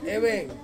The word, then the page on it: weng: Ansus